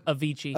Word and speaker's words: Avicii: Avicii. – The next one here. What is it? – Avicii.